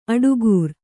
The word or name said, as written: ಅಡಿಗಾರ